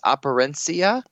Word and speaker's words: Operencia: And then a game I can't pronounce, Operencia.